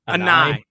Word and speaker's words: Anae: Offensive coordinator, is it Robert Anae? – Anae. Yeah.